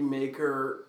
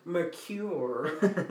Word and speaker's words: Maker: So, you know, it is McCurr or McCure or whatever, but he wants it to be Maker because it's easier. Everyone sounds dumb as hell when they have to, like, pause for a beat and say, Thon McCure. And, like, that's the right way to pronounce it.